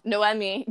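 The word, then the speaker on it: Noemí: But, and then, because then Naomi gets to, nope, nope, nope, said that wrong. Noemi gets to shoot, like, she shoots him and he still doesn't die.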